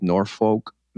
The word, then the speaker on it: Norfolk: Originally from a little town in Massachusetts called Norfolk, Massachusetts. It's Norfolk, but it's spelled Norfolk, right outside of Foxborough.